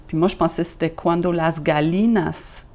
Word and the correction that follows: gallinas: tengas, tengan dientes, mais c'est cuando las gallinas tengan dientes.